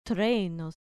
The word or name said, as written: threnos